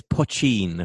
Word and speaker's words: poutine: Am I saying that correctly? – Well, it's Poutine, apparently.